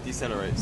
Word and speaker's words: decelerate: Yeah, so we just bore away, and it's always tricky because then we de-accelerate? How do you say that? – Decelerate, slow down.